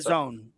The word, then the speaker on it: DAZN: Now he was having a post game interview with Dazn. Did I say that right? D-A-Z-N. Is that how they pronounce D-Zone. – It? D-Zone. Is that what it is?